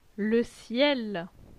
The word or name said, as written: Le ciel